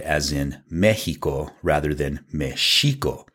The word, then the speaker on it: Mexico: that X was placed there because Mexico is supposed to be pronounced Mexico. The X makes that SH sound.